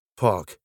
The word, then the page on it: park: British English